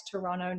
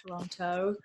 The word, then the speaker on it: Toronto: Toronto, not Toronto.